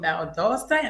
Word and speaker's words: Valdosta: Up next, we have Queen Thickums, all the way from Valdosta.